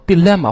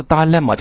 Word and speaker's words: dilemma: 那第二个词再举例子是 problem，这个单词很简单， problem problem this problem that problem，但是 problem 这个单词很普通，我们用另外一个单词去替换，在某些时候某些场合，比如说 dilemma 或者 dilemma，这个单词两个读音 D-I-L-E-W-M-A， dilemma dilemma